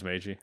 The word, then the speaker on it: Meiji: Uh, the only one I know for sure is it's Meiji Restoration. It is Meiji.